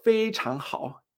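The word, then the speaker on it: fei chang hao (非常好): O por ejemplo, cuando yo digo fei chang hao, este fei chang, súper, fei chang, o muy, ¿no? Súper bien, fei chang hao. Fei primero, chang segundo. Fei chang.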